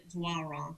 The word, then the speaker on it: Doiron: I don't think anyone in the Coastal Bend area has ever introduced me as Joslyn Doiron.